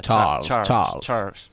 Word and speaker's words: Charles: Charles, no sé cómo se pronuncia. – Charles. Tienes que poner el dedo meñique elevado y pronunciarlo. Charles.